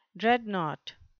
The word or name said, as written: dreadnought